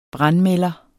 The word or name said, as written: brandmelder